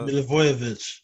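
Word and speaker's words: Milivojević: Was it Milojevic or whatever? – Milivojevic. – Yeah, I don't know how to pronounce that.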